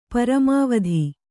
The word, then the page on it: ಪರಮಾವಧಿ: paramāvadhi